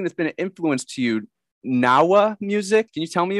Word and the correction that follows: Gnaoua: Well, it's mostly pronounced Ganawa, G N A O U A.